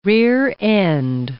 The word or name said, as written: rear-end